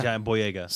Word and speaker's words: John Boyega: John Boyega? – Yeah. – Yeah. – Did I say it right? – Yeah. John Boyega.